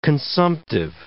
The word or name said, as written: consumptive